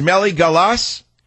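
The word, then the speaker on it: Meligalas: The pronunciation is Meligalassa. Meligalassa.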